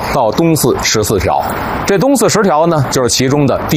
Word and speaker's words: 东四十条: 还有今天呢咱们来到的这个地方东四十条，这是标准发音啊，但是您得琢磨琢磨这怎么断句呀。